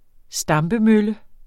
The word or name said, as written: stampemølle